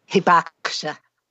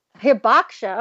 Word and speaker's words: Hibakusha: Is it Hibakusha? – Hibakusha. – Hibakusha. Oh, wow. I really butchered that.